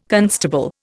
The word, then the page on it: constable: Indian